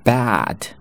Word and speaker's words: bad: 那 bad 张得很大，bad bed bad bed 是这样子。